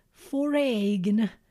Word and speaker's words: foreign: Careful with the pronunciation of the word foreign. It's not foreign, it's foreign. Think about number four, foreign, foreign. When something is foreign, it's not familiar to you.